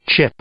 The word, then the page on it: chip: verb